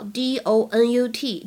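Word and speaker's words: doughnut: 在美式英语当中，大家也经常见到 d o n u t 这样的写法，会非常的简单，donut 发音一样。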